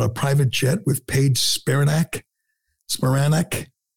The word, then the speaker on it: Spiranak: Am I saying that right? Spiranak? – I believe it's Spiranak, but a lot of people.